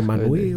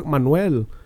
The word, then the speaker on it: Manuel: I was like, Manui, what the fuck? Manuel.